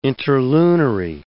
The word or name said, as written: interlunary